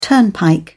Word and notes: turnpike: British English

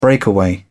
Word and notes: breakaway: British English

